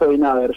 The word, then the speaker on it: Abinader: Sí, porque hubo elecciones y ganó Luis Abinader, ¿se pronuncia así? – Exactamente, Luis Abinader, yo no le veo Abinader. – Si yo lo pronuncio Abinader, como bien. Escucho que lo dicen los dominicanos.